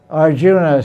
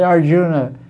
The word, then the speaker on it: Arjun: It's spelled Arjuna. So Western people say Arjuna. It's not correct. It should be Arjun.